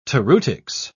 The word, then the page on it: toreutics: British English